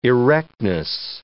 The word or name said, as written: erectness